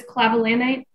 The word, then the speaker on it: clavulanate: What is clavulanate? – That is correct.